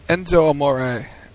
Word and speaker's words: Enzo Amore: I'm also curious to see, and I never say his name right, Enzo No Amore and Big Cass. – Enzo Amore and Big Cass.